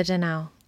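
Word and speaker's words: Donau: Yeah, or the Danau. – So the Danau. – That's like the German.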